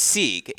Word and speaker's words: Sieg: How do I pronounce Maddie's last name? Because Sieg. – God, it's Sieg. It's Sieg. Okay. Mm-hmm. Clarification, college tennis fan. Sieg, we now know officially, and I have a feeling we're going to be saying that name a bunch.